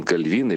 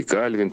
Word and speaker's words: Кальвин: В свое время Жан Кальвин или Кальвин, по-разному ударение, он тоже таким критерием вывел благочестивой жизни именно то, что у человека и материально присутствует благополучие, и какие-то другие сферы все хорошо идут.